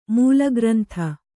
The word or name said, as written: ಮೂಲಗ್ರಂಥ